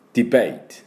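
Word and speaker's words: debate: でポイントはトゥトゥトゥトゥトゥトゥ、マシンガンまたは工事現場の、ドリルのトゥトゥトゥトゥトゥトゥトゥトゥ、短くて強く、これです。だからディベートじゃなくてトゥトゥトゥトゥトゥ、ディベイト、ディベイト、こんな感じで、ディッディッディッディッディッディッですね。ディベイト。ベイのところにアクセントがあります。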